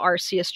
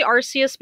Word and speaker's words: Arceus: which is supposed to reference how Arceus changes its type. Sorry, I say Arceus because it's a habit of mine and it's closer to the Japanese pronunciation, but yeah, it's Arceus. I don't know, whatever.